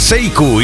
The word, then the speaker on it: Sekou: llama Seiku. Y si quieres localizarlo, que sepas que se escribe Sekou, así, con K y todo.